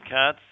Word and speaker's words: Katz: The book is called Being the Strong Man a Woman Wants, Timeless Wisdom on Being a Man by Elliott Katz. Last name is spelled K-A-T-Z.